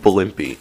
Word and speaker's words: Blimpie: Then, there's an actual submarine restaurant chain called Blimpy, except that it's not spelled B-L-I-M-P-Y, it's spelled B-L-I-M-P-I-E.